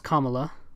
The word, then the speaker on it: Kamala: He's talking about her and he keeps saying Kamala, and it's pronounced Kamala, like Kamala. But he keeps calling it her Kamala.